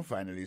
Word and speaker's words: finally: Finally, c'est F-I-N-A-L-L-Y, finally. T'as des prononciations, oui? Finally. Accent tombé sur premier syllabe là. Finally. F-I-N-A-L-L-Y.